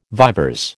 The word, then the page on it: vivers: American English